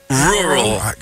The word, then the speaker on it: rural: That was excellent pronunciation on the word rural. – Rural. – Rural. It's a really hard word.